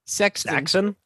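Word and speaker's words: Saxaon: Nathan Saxon, or how do you say that? – Saxon? – I guess it's supposed to be Saxon, but it's S-A-X-A-O-N. – Saxon, yeah.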